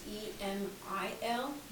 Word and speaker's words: Emil: It's E M I L, and it's pronounced Emil.